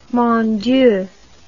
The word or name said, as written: mon dieu